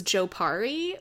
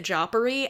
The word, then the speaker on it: Jopari: as I pronounced it mentally when I read the books, was Jopari, but in the show, they pronounce it Joppery, and every single time I, like, pee my pants laughing, because it's, like, this serious thing.